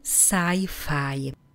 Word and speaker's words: sci-fi: Sci-fi. Česky se to píše úplně stejně jako anglicky, ale výslovnost je zcela jiná.